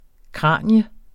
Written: kranium

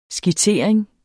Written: skitsering

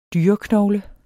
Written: dyreknogle

